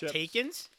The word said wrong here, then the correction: Takis: Takis? Takis? Wow, where did you get these?